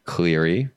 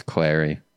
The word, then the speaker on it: Clary: You know what's funny is I had Laura Clary on my podcast and I mispronounced her name. – What'd you say? – Cleary. – And it's Clary? – It's Clary. That's a tough one though, because I just always pronounced her name wrong.